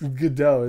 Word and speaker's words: Gadot: Gal Gadot. – Was there. It's Godot, isn't it? – What if she played... – That's how you say it.